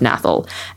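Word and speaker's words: gnathal: There's a silent G at the start of nathal.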